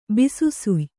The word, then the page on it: ಬಿಸುಸುಯ್: bisu suy